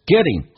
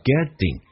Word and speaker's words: getting: getting 后鼻音经常可以发成前鼻音叫 getting，或甚至可以发成 n 这个发音叫 getting， getting 或 getting 都是可以。